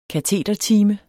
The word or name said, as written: KATEDERTIME